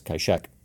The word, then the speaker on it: Kai-shek: In the early 20th century, his mentor, Sun Yat-sen, used the Cantonese pronunciation of his name, and thus the outside world came to know him as Kai-shek.